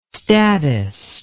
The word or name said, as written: statist